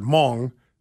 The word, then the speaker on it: Hmong: Apparently, I mispronounced the word Hmong. Somebody writes, the H is silent, you dumb F word.